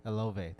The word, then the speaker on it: Olave: Is it Ova? Did I say it right? – I'm going to go with Chris Olave. – Olave, thank you.